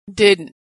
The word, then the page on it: didn't: American English